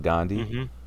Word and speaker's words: Gondi: The Canada Goose Gondi. I think I'm pronouncing it right. – Yeah, it was like a, it's like a matzo ball soup. But it's the Persian Jews call it Gondi, mm-hmm. – G-O-N-D-I.